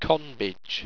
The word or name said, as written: conbage